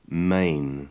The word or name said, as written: main